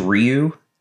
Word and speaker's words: Ryu: Actually, all right, so not to be a nerd, the real way you're supposed to say it is Ryu. – Okay, but the American way you say Ryu. Okay. But I it's Ryu.